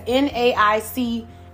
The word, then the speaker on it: NAICS: And yes, NAICS, it rhymes with snakes. So that's going to be a great way for you to remember. NAICS, that's it. NAICS rhymes with snakes, okay?